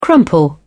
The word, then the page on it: crumple: British English